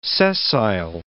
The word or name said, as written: sessile